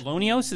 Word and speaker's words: LONEOS: LONEOS, is that how you pronounce it? – Yeah, the Lowell Observatory Near Earth Object Search.